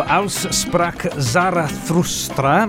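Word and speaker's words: Also sprach Zarathustra: La versione fantastica di Diodato, Aus Sprach Zarathustra, non so com'era la mia pronuncia, Luca, va bene? – Buona.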